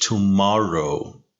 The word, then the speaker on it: tomorrow: see you later فقط دقت کن تو لغت tomorrow تاکید روی سیلاب دوم لغت هستش tomorrow